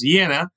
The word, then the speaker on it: Jena: Or in Louisiana, they can drive through the city of Jena, Louisiana, although it should be pronounced Jena, also named after the Great Battle that Napoleon won against the Prussians.